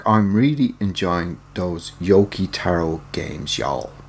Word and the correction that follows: Yoko Taro: Yoko Taro.